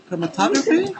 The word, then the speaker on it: chromatography: Chromatography? – Yeah.